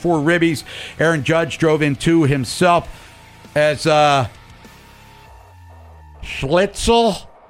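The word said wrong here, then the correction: Schlittler: right? – Are you looking at it or are you not? – I'm trying. I wrote it down phonetically. – I mean, no, you couldn't do Higashioka either, so yeah, I guess right. It's Schlitler. – Schlitler.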